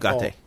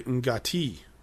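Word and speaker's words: Ugarte: We meet Peter Lorre's character, whose name I cannot pronounce at Ugate. – All. Ugate. – Ugate. – I was about to say Ungati, with an N in there and then a T at the end.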